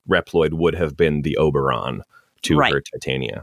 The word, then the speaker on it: Oberon: Is it pronounced Oberon? How's it pronounced? Oberon? – Oberon, yeah.